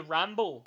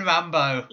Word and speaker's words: Rumble: And you pronounced it wrong. It's the Ramble, the New Japan Ramble, the New Japan Rambo.